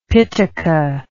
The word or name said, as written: pitaka